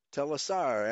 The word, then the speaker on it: Telassar: Telassar, accent on the last syllable here, a region of Assyria.